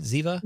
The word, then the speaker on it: Ziva: Am I saying that right? Ziva? – You are saying that right.